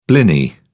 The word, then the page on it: blini: British English